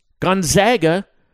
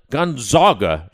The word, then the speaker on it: Gonzaga: And who knows, might he actually know how to pronounce Gonzaga rather than Gonzaga, as he was saying last year.